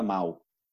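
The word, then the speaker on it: Mahou: Mau.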